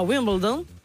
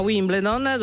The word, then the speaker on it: Wimbledon: Guardatevi le immagini del principino Giorgio a Wimbledon, perché sì, si pronuncia così, no? A Wimbledon, durante, credo, la finale, con un ventilatorino portatile bianco praticamente uguale al mio.